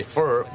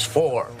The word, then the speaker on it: four: And uh, and then uh, there was another little word that he always kicked me whenever I said it wrong was F-O-R. I used to say fur, but it's four.